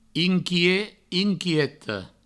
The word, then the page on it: inquiet, inquieta: inquiet, inquieto; Provençal